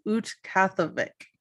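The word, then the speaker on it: Utqiaġvik: Okay, so the border of the Alaskan Triangle stretches from Utqatigiit, which I had to look up how to pronounce these, so I'm sorry if they're not right, but Google pronunciation says Utqatigiit.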